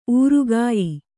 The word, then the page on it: ಊರುಗಾಯಿ: kannada